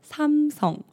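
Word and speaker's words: 삼성: Apple 就 Apple 这个公司，那大家知道三星怎么念吗？ 맞 아 요 바 로 삼 성 Samsung 这个我们之前在很多科技新闻也有讲过，不会念 Samsung 哦，我们会念 Samsung Samsung。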